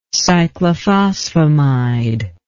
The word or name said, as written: cyclophosphamide